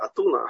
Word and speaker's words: Атуна: Хахмей Атуна, были такие, такое понятие. Атуна, Афины, у нас неправильное произношение, правильно, все-таки по-русски неправильно. Эсфирь, а не Эстер, и Руфь, а не Рут.